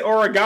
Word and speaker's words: Oregon: He said Oregon.